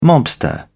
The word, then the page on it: mobster: British English